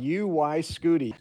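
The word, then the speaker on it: UY Scuti: The largest known star may be a red supergiant named Oyskudi? I don't think I said that right. – Star names are always less interesting than you think. It's called Uyskudi.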